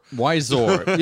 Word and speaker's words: Wiseau: There's no R in Tommy Wiseau. No, like, where would you get W-I-S-E-A-U.